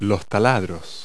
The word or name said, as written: drills